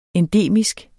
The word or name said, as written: endemisk